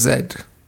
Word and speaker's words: Z: I was saying to Yazdi, I think it's Zed because it was an English explorer. – Yeah. I think it is Lost City of Zed. So we shall anoint it Lost City of Zed.